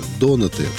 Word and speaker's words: донаты: Послушай меня внимательно, когда ты приносишь донаты, да, о животных, ударение, правильно, донаты, донаты, донаты, вкусные, с начинками.